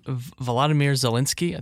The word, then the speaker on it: Volodymyr Zelensky: Well, Ukraine recently elected a new president, a comedian, Volodymyr Zelensky.